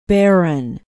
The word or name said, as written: barron